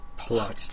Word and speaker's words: Palau: Palau.